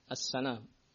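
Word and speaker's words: سنام: Sementara makna sanam di fathah sinnya, cara membacanya as-sanam. Fathah sinnya.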